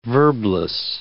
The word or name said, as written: verbless